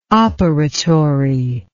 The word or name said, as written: operatory